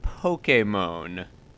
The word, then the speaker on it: Pokemon: That is how the word is. It's a Japanese word. It is pronounced Pokemon.